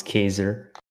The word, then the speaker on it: Khaizer: Sup, it's Khaizer, but sup. – Fuck, what's up, guys? – It's Khaizer and Harlee, guys. Say what's up. – Sup. He pronounced my name wrong. It's Khaizer.